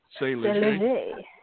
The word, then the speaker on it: Saint Leger: Nah, it's Saint Leger, but it's the French pronunciation is Saint Leger. – Saint Leger.